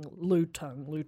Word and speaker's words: lutung: How would you say that? L-U-T-U-N-G. – L-U-T. – Yeah, lutung.